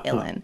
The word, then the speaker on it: uilleann: Like the Illan pipes are like U-I-L-L-E-A-N-N. There, I think there's two N's, but you say Illan.